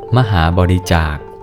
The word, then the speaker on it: มหาบริจาค: มหาบริจาค